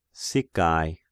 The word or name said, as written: Sicae